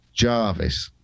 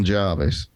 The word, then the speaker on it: Jervis: That's under the command of Admiral Jarvis. – Yes. – Sir John Jarvis, which I believe is spelt Jervis, isn't it? – Jervis, yeah.